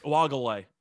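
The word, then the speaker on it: Uiagalelei: Then again, I also said Ugalele, or however you correctly pronounced it. Uagalele.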